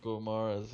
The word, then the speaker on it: Guimarães: Guimarães or something? – Guimarães, yeah.